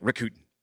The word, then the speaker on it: Rakuten: And at the end, it would be like Rakuten.